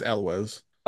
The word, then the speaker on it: Elwes: It's pronounced, according to Google, it is Elwes.